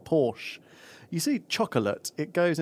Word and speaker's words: Porsche: Porsche is a rather nice word. – Hang on, say that again. – Porsche. – Porsche. Porsche. I don't like Porsche. – That is the correct pronunciation of the automobile, isn't it? – Luke doesn't like Porsche.